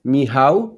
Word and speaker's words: Michał: In Polish, it's Michał, like me doing something, how to do something.